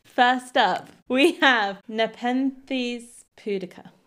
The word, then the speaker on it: pudica: So it's Nepenthes pudica. Might be pronounced like that. – Pudica. – P-U-D-I-C-A. Pudica. – Pudica. Like the old barbarian queen Boudica, if she had an accident and didn't make it to the loo in time.